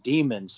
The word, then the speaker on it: daemons: but daemons are different than demons. So I don't know if, That's the Greek version of demon. It's pronounced the same. It's pronounced the same. Believe it or not, it is pronounced demon.